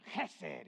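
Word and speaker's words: chesed (חסד): And he said that so we would all remember, and I'm doing the same thing so you would remember, chesed! And he said, if you don't spit on somebody, you're not saying it right.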